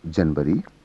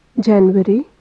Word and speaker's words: जनवरी: यहाँ पुरुष स्वर में गलत तथा महिला स्वर में इनके शुद्ध उच्चारण हैं। इन अंतरों पर विशेष रूप से ध्यान दीजिए। जनवरी जनवरी,